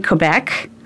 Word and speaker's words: Quebec: So May 16th through the 19th, and that's in Quebec. – Mm-hmm. – Am I saying that right? You know, and, you are.